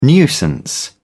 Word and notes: nuisance: British English

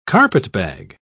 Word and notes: carpetbag: British English

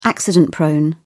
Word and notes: accident-prone: British English

